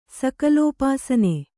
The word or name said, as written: ಸಕಲೋಪಾಸನೆ